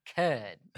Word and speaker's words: Cudd: I'm like, Cudd. – You have to say it like that. Cudd. – My name is Emily Cudd.